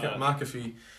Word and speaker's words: McAfee: Although Pat McAfee, if that's how you pronounce his name right. – McAfee, former NFL football linebacker, I believe.